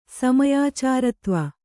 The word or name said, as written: ಸಮಯಾಚಾರತ್ವ